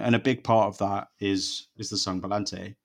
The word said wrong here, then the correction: Palante: Palante, she seems to say, you, where's the Spanish expert, Matt? How do I pronounce it? – Palante.